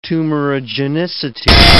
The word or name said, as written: tumorigenicity